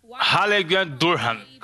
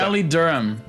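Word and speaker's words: Raleigh-Durham: Fala o nome do aeroporto aí. Como é que você acha que se pronuncia? – Hallegan Durham, Carolina do Norte, Estados Unidos. – Não é muito longe disso, não. – Fala certo agora aí, você que tem o inglês brutal. Raleigh Durham.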